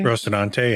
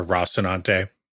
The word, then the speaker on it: Rocinante: Rocinante? – Rocinante, yeah. Or Rocinante.